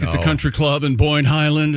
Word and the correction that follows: Boyne Highlands: First of all, it's pronounced Boyne Highlands.